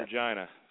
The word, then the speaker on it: Regina: It's actually pronounced Regina, so we get a few jokes, but Regina.